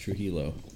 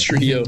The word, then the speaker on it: Trujillo: Robert, I think it's pronounced Trujillo. Trujillo? – Yeah, probably. I think it's Trujillo.